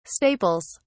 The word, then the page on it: staples: British English